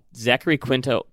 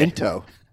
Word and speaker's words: Zachary Quinto: But still, Kevin Spacey, Jeremy Irons, Paul Bettany, Zachary Quinto? I don't know how to pronounce his name. Penn Badgley, who is... – I actually think the Q is silent. It's okay. Into.